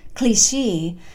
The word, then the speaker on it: Clichy: Clichy, as it is apparently pronounced, is near the Arc de Triomphe, where I eventually rented a garret apartment.